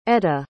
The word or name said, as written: etta